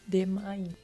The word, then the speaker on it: Demain: Demain. – Demain, como se escreve? – Isso. D-E-M-A-I-N.